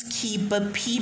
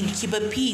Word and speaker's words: Kibapi: Oh, so on Facebook I use Kibapi Myesgin. Kibapi is spelled as K I B A P I while the surname is Myesgin, M Y E S G I N.